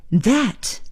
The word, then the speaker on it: that: 那个用英文说是 that， T H A T that。在这里呢，我们又看到了 TH 字母组合的发音，那么还是要提醒大家，舌尖呢一定要在上牙齿和下牙齿中间，试着来发一下 That That 非常非常好。